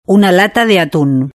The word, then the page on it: una lata de atun: European Spanish